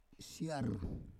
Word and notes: siar: Roon

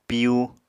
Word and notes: piyu: Apatani

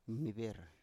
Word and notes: mniber: Biak

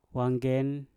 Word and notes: wangen: mpur

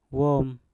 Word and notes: wom: mpur

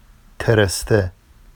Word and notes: tereste: Vafsi

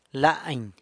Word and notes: lany: sanlucasquiavini

